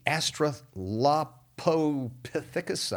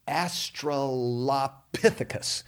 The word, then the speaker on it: Australopithecus: Then these human-like creations, like the Neanderthal and the Australopithecus, I didn't say that right, but that's okay. Let me try that one more time. I think it's called the Australopithecus.